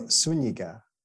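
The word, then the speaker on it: Zuniga: It's Z is soft, like as in sugar. Okay. So Suniga.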